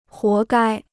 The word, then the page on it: 活该: huó gāi